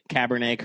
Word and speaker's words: Cabernet: I love that you pronounced it Cabernet correctly, but yeah. – somehow Merlot slipped through the cracks.